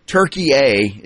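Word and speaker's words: Türkiye: Turkey A is how it's always pronounced.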